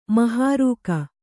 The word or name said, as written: ಮಹಾರೂಕ